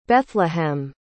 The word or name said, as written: Bethlehem